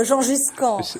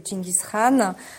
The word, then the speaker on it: Genghis Khan: Alors moi je dis Genghis Khan, et comment dites-vous? – En France, on parle de Gengis Khan. On parle de Gengis Khan parfois aussi. Mais c'est vrai que la prononciation, sinon mongol et turc, c'est Genghis Khan.